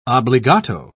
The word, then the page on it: obligato: British English